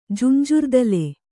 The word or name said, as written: ಜುಂಜುರ್ದಲೆ